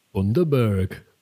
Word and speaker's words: Bundaberg: Like, it wasn't as sweet as a Bundaberg.